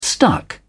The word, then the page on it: stuck: British English